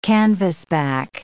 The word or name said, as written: canvasback